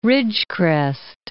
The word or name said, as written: Ridgecrest